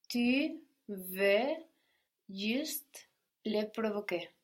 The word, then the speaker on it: juste: Juste. So spelled just like the English, but with an E on the end to let us know to pronounce that final consonant. Juste.